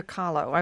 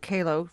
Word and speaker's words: Kahlo: You have corrected my pronunciation of the artist Frida Kahlo. Frida Kahlo. I've always called it Kahlo.